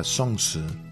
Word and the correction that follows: zongzi: Zongzi. – Zongzi. – Zongzi. – Zongzi. – Sí, dos seda, Zongzi.